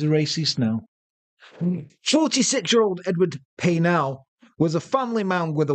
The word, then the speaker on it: Paisnel: I believe that's pronounced Paisnel. – Is it? – You don't pronounce the ass.